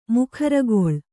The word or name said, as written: ಮೊಖರಿಗಾಳೆ